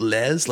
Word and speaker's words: Leys: So his name is Bruno Lez. Is that how you say it? Lez, like L-E-Y-S.